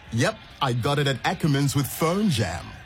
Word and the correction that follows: PhoneYam: Brawaka, it's phone yum.